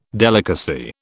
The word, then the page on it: delicacy: British English